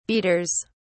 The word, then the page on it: beaters: British English